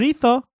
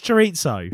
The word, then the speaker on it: chorizo: Come chorizo. Fuck off, it's chorizo.